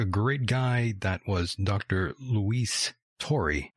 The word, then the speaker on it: Turi: Luis Turi. That's the way they would say it down south, but his name is Luis Turi. For those who were wondering, that is the proper pronunciation here.